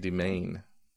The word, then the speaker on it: demesne: It's D-E-M-E-S-N-E, like Demesne. – D-E-M. – It's spelled Demesne. – Okay. – But it's pronounced Demain.